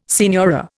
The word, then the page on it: signora: Indian